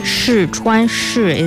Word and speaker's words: 试穿: 试穿试 is the fourth tone. 穿 is the first tone.